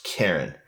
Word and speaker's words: Karin: Dear Ashley, my mom's name is K-A-R-I-N, pronounced Karen.